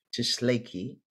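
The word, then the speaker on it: Tsalagi: Tshlaki is the appropriate name, but Americans say Cherokee.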